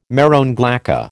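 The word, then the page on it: marron glacé: American English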